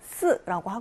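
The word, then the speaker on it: 스 (四): 사자, 스라고 하고요. 얘는 죽을 사자이죠. 죽을 사자, 스. 중국어에서는 삼성으로 읽는데요. 이 죽다라는 뜻의 스와 발음이 비슷해서 숫자 4를 싫어합니다.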